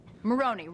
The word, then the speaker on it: Maroney: Maroney rhymes with baloney.